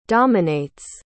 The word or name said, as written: dominates